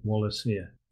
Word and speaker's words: Wallacea: I had difficulty knowing how to pronounce this, and I'm told that the organisation and themselves pronounce it Wallacea.